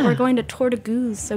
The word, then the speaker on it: Goed: Okay, Goose. – It's like booze, but with a G.